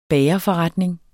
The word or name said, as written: bagerforretning